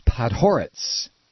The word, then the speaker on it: Podhoretz: It's Podhoretz, Norman Podhoretz, Reggie writes. That's how you say it with the accent on whore, Podhoretz.